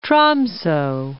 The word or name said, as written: Tromso